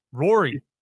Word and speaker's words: Rory: Rory. – If he wins it, it'd be great. – There's not a greater story than Rory, unless Tiger. – Right. And Rory rhymes with story.